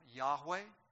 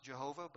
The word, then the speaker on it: Jehovah: Many people pronounce it Yahweh. In the 16th century, someone thought it should be pronounced Jehovah. It was really actually a copyist mistake. And we know that it's really not pronounced Jehovah, but that's what many English people use.